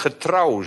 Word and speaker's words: getrouw: Maar voor zover wij over weinig getrouw zijn geweest, je ziet de klemtoon ligt op trouw. Niet op het hoeveelheid, maar op trouw.